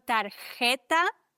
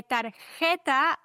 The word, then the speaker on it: tarjeta: Bei tarjeta, achte da bitte drauf, da ist ein J in der Mitte des Wortes. Und ein J wird im Spanischen wie so ein CH ausgesprochen. Das muss im Hals kratzen.